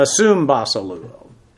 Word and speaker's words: sumbasileuo: Here we have, and because of phonetic reasons and all of that, the way the Greeks like to pronounce, this is actually a sumbasileuo. S-U-M-B-A-S-I-L-E-U-O. Basileus is a king. Basileuo means to reign as a king. Sum, this just is because, again, they didn't like sumbas.